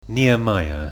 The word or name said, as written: Nehemiah